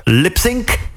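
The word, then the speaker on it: Lipps Inc: Daarvoor hadden we ook nog lip-sync. Zo mag je dat in één track uitspreken, want het was dus op dat lippen bedoeld. Niet Lips Incorporated, zoals velen dachten.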